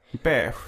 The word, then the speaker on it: beige: Beige? Jaha, jag, jag fattar inte ditt uttal. beige. Beige. Beige.